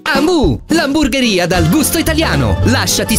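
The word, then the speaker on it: Hamu: Amu si scrive con l'H.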